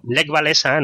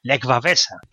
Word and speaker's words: Lech Wałęsa: Por ejemplo, Lech Walesa no se dice Lech Walesa, sino que se dice Lech Wałęsa. Pues lo mismo, Piłsudski se pronuncia Piłsudski, o algo por el estilo.